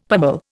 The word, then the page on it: pummel: Indian